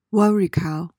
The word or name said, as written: worricow